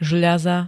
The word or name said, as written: žľaza